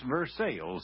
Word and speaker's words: Versailles: I know it's Versailles for those of you who are French bred, but here in Ozark land, we don't always pronounce names like they do in other parts of the country, and although it's spelled the same, it's Versailles here in our neck of the woods.